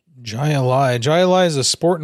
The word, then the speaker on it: Jai Alai: Jai Alai, I think is how you pronounce it. – Jai Alai.